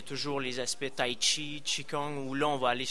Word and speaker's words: qigong: Le qigong.